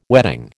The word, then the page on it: wedding: American English